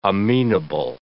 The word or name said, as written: amenable